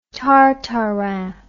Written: tartarin

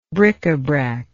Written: bricabrac

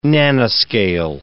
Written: nanoscale

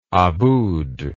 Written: Abboud

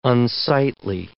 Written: unsightly

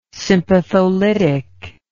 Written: sympatholytic

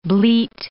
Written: bleat

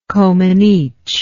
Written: comaneci

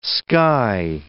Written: sky

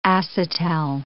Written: acetal

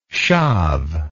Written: schav

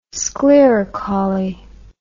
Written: sclerocauly

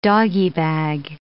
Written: doggie bag

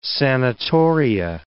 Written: sanatoria

